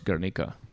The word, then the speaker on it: Guernica: And so it's pronounced Guernica.